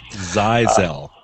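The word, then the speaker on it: Zyxel: So the big change recently was the Zyzel, I finally found out it's pronounced, firewall. – Zyzel.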